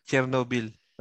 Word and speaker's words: Chernobyl: hmm, parang Chernobyl, no? Tama ba ako ng pronunciation? – Che- che- uh, Chernobyl. – Oh, Chernobyl.